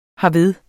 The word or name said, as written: herved